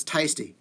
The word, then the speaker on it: TSTY: Okay, so it's tasty.